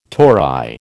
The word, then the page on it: tori: American English